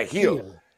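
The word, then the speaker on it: Gil: And speaking of Gill, so Luis, and I think you don't pronounce it, right? Yeah, Heal.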